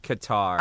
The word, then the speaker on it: Qatar: Anyway, if you want to ask a Middle Eastern guy, I'll tell you it's pronounced Qatar.